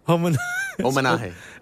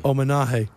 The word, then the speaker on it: Omanahe: Omanahe. It's called Omanahe. – But, well, the H is silent, so it's O. – Wait a minute. – Omanahe. – Omanahe.